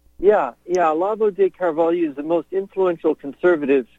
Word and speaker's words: Carvalho: The first one is titled "An Interview with Brazilian Writer Olavo de Carvalho." – Carvalho is how they pronounce it. Olavo de Carvalho.